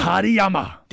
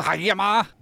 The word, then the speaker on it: Hariyama: Hariyama! – Det er løgn, må jeg se. Du får det tilbage. – Hariyama! – Hvad er det med jer? – Det er sådan, det skal udtales, det er altså ikke.